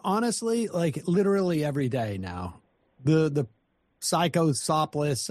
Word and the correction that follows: Cyclospora: I can't pronounce it, is still Cyclospora.